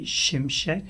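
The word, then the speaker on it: Şimşek: Is that how you say your name? Or It's actually Shimshek. – Shimshek. – Like the S's are like SH sound, but I dropped them a long time ago coming to US.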